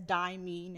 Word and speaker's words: Dimeyy: I'm rebranding as Dimeyy now. – Spell it. – D-I-M-E.